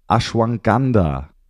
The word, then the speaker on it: Ashwagandha: Das Thema Ashwagandha.